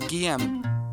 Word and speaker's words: Guilhem: It's GUILHEM, and it's pronounced GM.